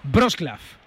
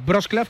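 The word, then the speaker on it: Wrocław: Wroclaw. O Breslavia, que es españolizado, vale. O Wroclaw, que es en su idioma.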